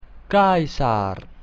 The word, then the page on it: Caesar: Latin